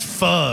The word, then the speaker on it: pho: It's spelled P-H-O and people are like, it's pronounced pho, and they get all upset.